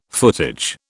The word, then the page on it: footage: British English